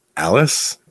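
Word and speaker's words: Ales: In 1891, Antonio Gramsci is born in Alice. Is that? – Yeah, that's okay. – Spelled like Ailes, province of Cagliari, Sardinia, on the 22nd of January, fourth of seven children.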